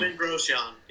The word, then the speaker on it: Romain Grosjean: Romain Grosjean, I'm probably... not pronouncing. – Roman Grosjean, yep.